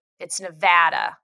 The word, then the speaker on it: Nevada: All of the trips to Vegas, I've been corrected because they said it's like pronouncing the word mad mod. It's Nevada. So I always have to remember mad.